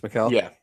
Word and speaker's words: Mikal: Yeah, and the same thing with Mikal Bridges. Is that how it's pronounced, Mikal? – Yeah.